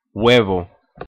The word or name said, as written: huevo